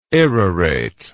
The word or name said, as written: irrorate